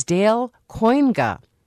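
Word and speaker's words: Dale Kooyenga: Our next guest is Dale Cuyenga. I mispronounced that earlier. Dale Cuyenga is a Republican state representative from Brookfield and a member of the Joint Finance Committee.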